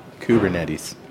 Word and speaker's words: Kubernetes: How do you spell that, Kubernetes? – K-U-B-E-R-N-E-T-E-S. – Okay. Kubernetes.